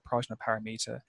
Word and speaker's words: Prajnaparamita: But in terms of our oldest manuscript is a palm leaf Buddhist manuscript from Nepal, which I believe the correct pronunciation would be something like Prajnaparamita.